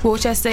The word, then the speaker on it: Worcester: In Worcester, I think that's how you say it. – Worcester, yeah.